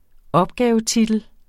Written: opgavetitel

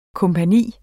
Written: kompagni

